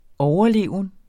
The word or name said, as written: overleven